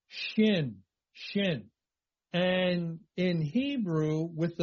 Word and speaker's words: Shin: There would be a dot above the right side of this letter that looks like a big W, and that would be pronounced Shin, just like the letter is Shin. And yet, there is also the same consonant, same letter with a dot on the left side at the top. And that's pronounced Sin. Sin. Again, it's more like Sheen and Seen.